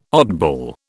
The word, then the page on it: oddball: British English